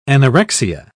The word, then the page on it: anorexia: British English